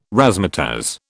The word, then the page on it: razzmatazz: British English